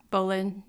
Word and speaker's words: Boleyn: I don't know how to say her name. Boleyn? – Cleves. – Boleyn, yeah.